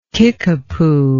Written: Kickapoo

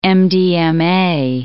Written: MDMA

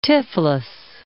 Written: Tiflis

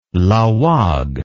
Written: laoag

